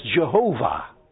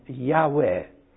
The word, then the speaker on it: Yahweh: And he uses the word Lord here not from Elohim, but Yahweh, that we used to read in our Bibles as Jehovah. And now all the scholars in the Jews and in the Christian world agree that that is a mispronunciation, that it should have been Yahweh.